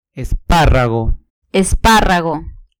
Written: esparrago

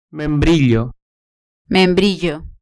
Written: membrillo

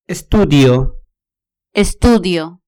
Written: estudio

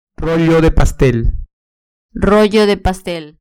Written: rollo de pastel

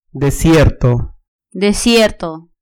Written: desierto

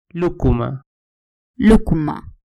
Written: lucuma

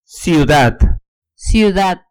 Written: ciudad

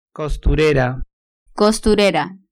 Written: costurera

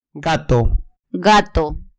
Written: gato

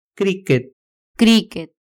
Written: criquet

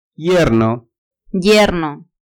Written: yerno

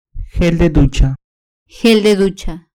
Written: gel de ducha

